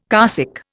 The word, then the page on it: carsick: Indian